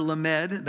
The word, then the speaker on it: Lamed: The letter Lamed, that's how you would pronounce it, means the staff or the rod or the cattle goad.